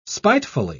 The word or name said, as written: spitefully